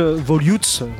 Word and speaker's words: Volutes: Euh, l'album s'intitule Volutes. Voilà, je le prononce comme ça.